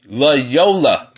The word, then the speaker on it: Loyola: North Carolina over Yolota. Some of these names are just weird to pronounce. I don't know. Maybe I'm just pronouncing it wrong. Anyway, they won 83-67. Loyola. That's how you pronounce it.